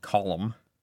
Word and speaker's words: Colm: And they say it like Colm. – Colm. – Colm. – Colm. – Almost like Colm, like instead of a row, but a column.